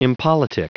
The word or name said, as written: impolitic